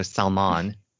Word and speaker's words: salmon: And I saw this meme, this GIF, I don't know, but it said, go ahead and pronounce the L in salmon.